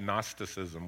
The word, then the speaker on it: Gnosticism: The popular philosophy of John's day and of the Roman Empire, for that matter, was something called Gnosticism with a G. Silent G, but Gnosticism nonetheless.